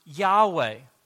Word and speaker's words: Yahweh: In the Hebrew, the Lord's name would be pronounced Yahweh.